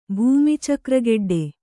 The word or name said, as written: ಭೂಮಿಚಕ್ರಗೆಡ್ಡೆ